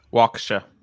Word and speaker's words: Waukesha: Like, you know, some like this Waukesha thing. It's is that how you say it? Waukesha? – Waukesha. – Waukesha. Yeah. Okay.